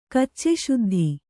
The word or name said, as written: ಕಚ್ಚೆಶುದ್ಧಿ